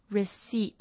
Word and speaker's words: receipt: 在很常很常用到的一个字是 receipt，它是发票，可是它的拼法是 receipt，很特别是它这边的 p 不发音 receipt， OK？